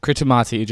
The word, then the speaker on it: Kiribati: This is called, it's spelled Kiribati, just like Kiribati is spelled Kiribati. But T-I in their language is S. There is no S in their alphabet, so they use T-I for that sound. So this is called Christmas Island, which if you look at the spelling, it's K-I-R-I-T-I-M-A-T-I, which is like Christmas.